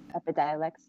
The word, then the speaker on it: Epidiolex: You said Epidiolex. Am I saying that right? – Epidiolex, yeah.